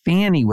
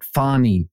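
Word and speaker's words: Fani: So the actual correction is, as a number of listeners sent me, the correct pronunciation of the district attorney for Fulton County, Georgia is not Fannie Willis, but Fonnie Willis. So it is not Fannie, it is Fonnie.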